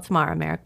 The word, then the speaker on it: Mara Marek: Yes, it's Mara Marek, but everyone says Mara.